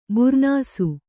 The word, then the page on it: ಬೂರ್ನೀಸು: būrnīsu